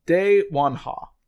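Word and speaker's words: Dae Won-Ha: Her name is Da Zhou Wan, which is spelled D-A-E-W-O-N. Mm-hmm. Oh, sorry. I looked at the wrong one. It's De Wan Ha. So D-A-E space W-O-N dash H-A.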